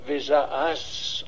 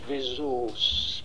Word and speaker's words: Vesaas: Alors son nom est difficile à prononcer, il s'appelle Tarjei de son prénom, Vesaas en français, mais avec deux A, mais les Norvégiens disent Vesaas, parce qu'il y a un petit O au-dessus du A.